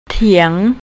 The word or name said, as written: teang